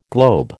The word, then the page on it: globe: American English